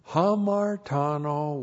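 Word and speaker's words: Hamartano (ἁμαρτάνω): It's correctly translated from, by the agency of, and then theos, God, and then here, hamartano, with the objective negative uk. H-A-M-A-R-T-A-N, long O. Hamartano.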